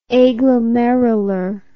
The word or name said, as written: aglomerular